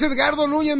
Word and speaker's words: Edgardo Núñez: Escarto Núñez. – A ver, otra vez, ¿cómo? – Escarto Núñez. – Ay, qué bonito lo pronuncia, ¿eh? Edgardo Núñez. Es Edgardo Núñez, mija, pero te lo vamos a valer porque sí te entendimos como quiera, ¿eh?